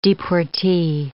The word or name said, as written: deportee